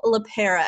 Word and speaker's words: LePera: And today I have a very special show for you guys, Dr. Nicole LePera. Am I saying that right? Nicole? – You sure are.